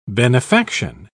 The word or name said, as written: benefaction